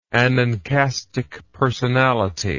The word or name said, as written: anankastic personality